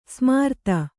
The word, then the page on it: ಸಮರ್ಥ: kannada